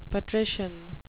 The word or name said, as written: patrician